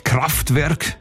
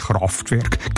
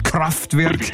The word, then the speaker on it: Kraftwerk: Kraftwerk, nicht Kraftwerk, Kraftwerk.